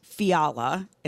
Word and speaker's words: Fiala: Police say his name is Matt Fiala. It's F-I-A-L-A, for those who would like to look it up.